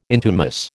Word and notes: intumesce: American English

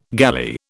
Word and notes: galley: British English

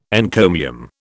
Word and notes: encomium: British English

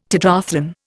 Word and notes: tetrathlon: Indian

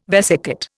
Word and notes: vesicate: Indian

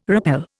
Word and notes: rappel: Indian

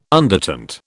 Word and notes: undertint: British English

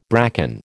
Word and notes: bracken: American English

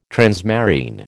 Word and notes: transmarine: American English

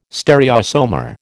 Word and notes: stereoisomer: American English

